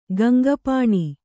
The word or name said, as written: ಗಂಗಪಾಣಿ